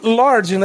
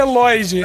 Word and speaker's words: Lloyd: chega o faxineiro, que é o Lloyd, e ele chamou ele de Lord, né? Senhor em inglês. É. Aí fala, Lorde, né? Aí, não, a pronúncia é Lloyd.